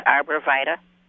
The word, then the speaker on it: Arborvitae: But I'm wondering, do most people pronounce it as the natives do, just Arborvitae? – Yeah, exactly.